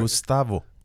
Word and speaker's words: Gustavo: Am I saying your name properly right now? – Kind of. – No, say it right. – Gustavo. – Gustavo. – Yes.